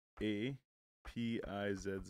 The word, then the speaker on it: apizza: And like it was In what's it called, New England, they spell it A P I Z Z A, but they pronounce it Apiz.